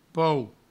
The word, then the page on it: pou: provençal